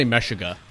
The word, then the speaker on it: Meshuggah: I say Meshuggah.